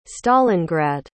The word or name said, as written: stalingrad